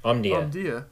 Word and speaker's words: Omdia: Yes, the iPhone 11 is the most popular phone in the first quarter of 2020, according to the research forum, Omdia, how do you say that? Omda? Okay. – Omdia. Omdia. – Omdia. – There you go.